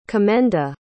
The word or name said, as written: commenda